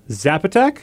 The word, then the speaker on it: Zapotec: Zapotec.